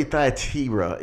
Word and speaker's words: Thyatira: And we're in the book looking at the church of Thyatira, or really Thyatira. It's really, there's really no long I's, and I shouldn't say in Greek.